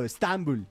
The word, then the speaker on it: Estambul: Nosotros le ponemos el acento en otro lado. Estambul. Así le decimos nosotros los más cercanos a esa tierra.